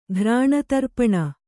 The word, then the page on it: ಘ್ರಾಣತರ್ಪಣ: ghrāṇa tarpaṇa